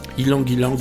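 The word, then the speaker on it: ylang-ylang: Ilang Ilang ou Ailang Ailang, vous le prononcez comme vous voulez.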